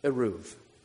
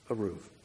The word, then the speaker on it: Eruv: It's spelled E-R-U-V. Eruv. Eruv.